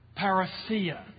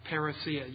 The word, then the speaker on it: Parousia: It's the Greek word Parousia. Let me spell that. P-A-R-O-U-S-I-A. – Parousia. You may hear it pronounced parousia by the Englishman. But it's parousia, the coming of the Lord.